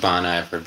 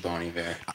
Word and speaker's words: Bon Iver: It means good winter. When Justin, the main dude, came up with the name, he just didn't know that winter was spelled with an H in French. – Oh, got that's it. – Funny. So, got it. – So he signed a letter to his friend, Good Winter, and just it looks like Bon Iver, but it's actually Bon Iver.